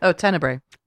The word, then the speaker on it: Tenebrae: Tenebrae. – Tenebrae. – No, I feel you because like most people have seen Suspiria. – Yeah. – Mm-hmm. Most people have seen Deep Red, but Tenebrae, a lot of people have missed.